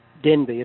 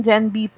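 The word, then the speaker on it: Denbigh: Denby. It's spelled D for dog, E-N-B-I-G-H, Denby Place, Harrington Park.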